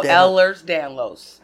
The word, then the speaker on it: Ehlers Danlos: To talk about, which was I don't remember how to pronounce it. The Elnors. – Oh, Ehlers Danlos.